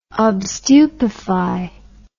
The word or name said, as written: obstupefy